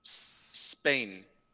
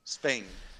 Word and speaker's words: Spain: si tú lo juntas, dirías in Spain, ¿no? – In no, Spain. in Spain. Eso es. In eh. Spain. Pues ahí es cuando me dio la clave de cómo se dice esa S. Es Spain.